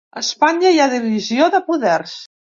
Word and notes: poders: catala

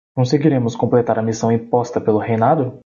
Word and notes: completar: portuguese

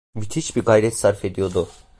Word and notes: sarf: turkish

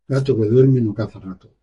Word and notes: caza: ispanyolca

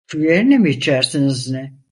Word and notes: yerine: turkish

